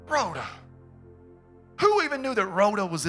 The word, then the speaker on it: Rhoda: Say it like that. Say Rhoda. Roll that R. Sounds a little dramatic. Rhoda.